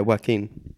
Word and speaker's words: Joachim: As in like Joaquin? – Yeah. – Yeah, Joaquin. – But it's got a CH in it. – Joachim? – Not at the front. – No, but it's J-O-A. – C-H-I-M. – Yeah, Joachim. Or Joachim. – Is that how it's pronounced? I call it Joaquin. – Yeah, but you're wrong. – Yeah, but tell that to Joaquin Phoenix, he'll knock you out.